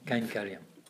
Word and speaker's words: kainkaryam: There's kamapanam and there's kainkaram. Is that, am I saying that wrong? Yeah, you say it. Kainkaryam.